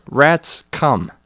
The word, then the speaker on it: Rathscum: "It's pronounced rats come, like rats come out at night and take the cheese. The H is silent, get it?"